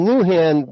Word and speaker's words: Lujan: The name Lujan works well because it rhymes with Looney Tunes.